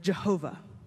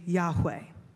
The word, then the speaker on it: Yahweh: That's how scholars for a long time believed we pronounced this. But as we've learned more about the Hebrew language, we know that a closer pronunciation of this name is actually Yahweh. That's the closest we can come without having the vowels to work with, Yahweh.